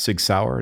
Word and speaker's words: SIG Sauer: And by the way, that is how it's pronounced, Sig Sauer.